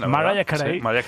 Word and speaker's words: Mariah: ¿Lo he dicho bien? ¿Mariah? – Sí, lo has dicho muy bien. – Mariah Carey.